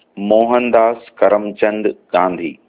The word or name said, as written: Mohandas Karamchand Gandhi